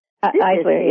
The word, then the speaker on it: Eisler: Rhianne Eisler, or Eisler, I do not know the correct pronunciation here. – Eisler, yeah.